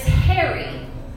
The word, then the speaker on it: heri: So how to say yesterday in Latin is hairy, H-E-R-I, long I.